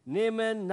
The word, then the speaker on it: Naaman: You pronounce it that way, Naaman? Naaman. Okay, Naaman, we'll stick to Naaman.